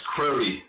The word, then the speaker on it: Crowley: Oh, it's Crowley, so it sounds like a long O.